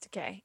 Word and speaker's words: Takei: They kind of gave it to Sulu as a nod to George Takei or Takei, however you pronounce it. Yes, Takei. Yep.